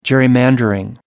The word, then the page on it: gerrymander: American English